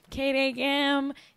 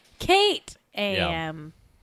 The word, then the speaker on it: JackAM: KDAM. If you don't know, if you're just tuning in, the show is spelled J-A-C-K-A-M, but it's pronounced Kate-A-M.